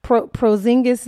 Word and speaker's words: Porzingis: Porzingis, that's how you pronounce it.